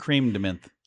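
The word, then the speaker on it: crème: It's C-R-E-M-E, which is not cream. It is creme. C-R-E-M-E. – I've always heard it creme de menthe. So, yeah. – I've heard it. – Cream is spelled C-R-E-A-M.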